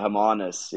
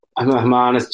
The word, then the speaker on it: Hermanus: Yeah, Hermanus. Yeah. Hermanus, just um, southeast from Cape Town.